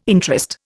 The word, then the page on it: interest: Indian